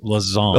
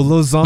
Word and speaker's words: Lausanne: It's Lausanne. – Oh, Lausanne.